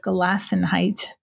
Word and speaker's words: Gelassenheit: And so I feel like part of the Anabaptist tradition that has really appealed to me is this concept of Gelassenheit.